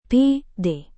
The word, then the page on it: pd: Indonesian